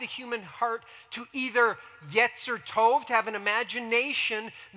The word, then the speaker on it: yetzer: Now, that word is the word yetzer. I think that's up. Next slide. Yeah, yetzer. Go ahead and say yetzer. Yetzer, it's how it's pronounced.